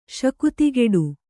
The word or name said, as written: ಶಕ್ತಿಗೆಡು